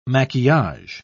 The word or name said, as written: maquillage